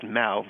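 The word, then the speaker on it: mow: Originally, I spelled it M-A-U, but I gave in to the crowd, and it's M-O-W, but spelled like Mo, but pronounced Mao, rhymes with cow.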